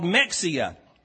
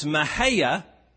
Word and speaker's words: Mexia: And the sign says M-E-X-I-A. And the guy driving says, well, look, we're coming up on a town called Mexia. And the passenger says, that's not pronounced Mexia, it's pronounced Mahia.